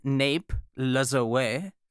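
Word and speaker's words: Lajoie: Napoleon Nape Lazoe, which that is the correct pronunciation, by the way. I found multiple sites on the internet saying that's how it was said, and all of them said, make it sound French.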